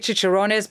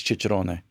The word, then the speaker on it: Cicerone: I want to say Cicerones because you're saying it right if you're saying the word in Italian. So I believe it's Cicerone. And we just sort of changed it in this country to become Cicerone. But go ahead.